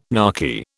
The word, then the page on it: narky: British English